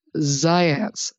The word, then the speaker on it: Zajonc: If I say his name, I'll have to spell it for everybody because I believe it was pronounced Ziance, Ziance, but it he was I think he was Yugoslavian or something, and it's actually spelled Z A J O N C.